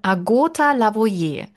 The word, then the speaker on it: Lavoyer: Die Betonung ist auf dem O. Agota Lavoyer.